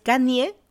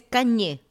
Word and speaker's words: Kanye: es Kanye West y en inglés Kanye West, como con una ñ ahí.